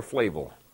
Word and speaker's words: Flavel: And John Flavel, when he caught, is it Flavel or Flavel? – Flavel. – Flavel, thank you.